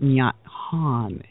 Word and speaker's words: Nhat Hanh: I looked up how to pronounce it, Nhat Hanh. It's T-H-I-C-H-N-H-A-T-H-A-N-H.